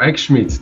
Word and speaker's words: Eckschmidt: Ah, perfeito. X-Schmidt, tá quase nativo.